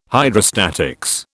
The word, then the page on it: Hydrostatics: British English